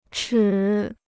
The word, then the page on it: 尺: chě